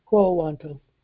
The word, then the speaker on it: quo warranto: And then he's also going to file a quo warranto. Am I saying it right? – Quo warranto.